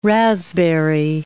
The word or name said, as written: raspberry